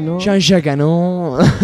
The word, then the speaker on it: Jean-Jacques Annaud: Jean-Jacques Annaud.